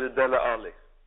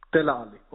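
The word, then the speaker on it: Dele Alli: Och sen får man inte glömma bort Ali, jag vet inte, hur uttalar man det på bästa sätt? Är det Ali? – Jag säger Dele Ali. – Dele Ali, okej, då säger jag också det.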